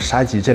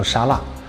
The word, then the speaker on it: 沙棘: 给这个顾客介绍沙棘的时候，很多人把沙棘这两个字还念错，念成沙辣，还以为沙棘是咱们内蒙的一个野菜。 – 沙棘有 13个亚种在中国有分布，为什么只有内蒙的沙棘品质最好？